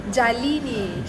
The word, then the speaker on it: Jalini: I'm here with Jelina? – Jalini. – Jalini. Oh, sorry. I mispronounced your name.